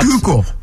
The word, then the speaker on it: Schuylkill: Do I pronounce Schuylkill right? I don't know. How do you pronounce it? – I think you pronounce it fine.